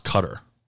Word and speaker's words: Qatar: But I think it's pronounced Cutter. Which is stupid. Growing up, it was Qatar. That's what we called it.